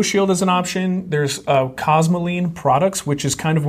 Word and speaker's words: Cosmoline: C-O-S-M-O. – C-O-S. – S-M-O. L-I-N-E. – L-I-N-E. Mm-hmm. Cosmoline.